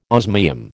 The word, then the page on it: osmium: American English